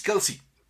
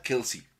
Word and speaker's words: Kelce: Entonces, todo el mundo le dice Travis Kelsey. Entonces. – En el oficial se le ocurrió decir, no, es que en realidad se pronuncia Kels.